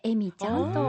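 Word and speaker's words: 笑味: エミちゃん、そうで、うん、エミちゃんって言ったらなんかエミちゃんが作ってる農園で美味しそうって私ずっと想像してたんですけれども、うん、はい、笑う味と書いてエミちゃんというふうに書かれていて、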